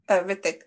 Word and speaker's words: Hrithik: So do you not pronounce the H at the beginning? – Hrithik. I just say Hrithik.